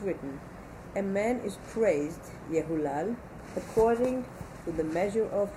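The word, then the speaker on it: Yehulal (יהלל): That's how it's read, but the way it's spelled without the vav, as spelled, continue. – As spelled, the word could be pronounced Yehalel.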